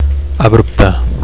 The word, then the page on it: abrupte: Catalan